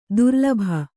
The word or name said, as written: ದುರಾಲಭೆ